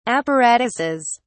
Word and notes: apparatuses: British English

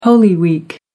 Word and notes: holy week: American English

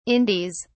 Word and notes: indies: British English

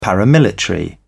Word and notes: paramilitary: British English

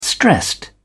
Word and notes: stressed: American English

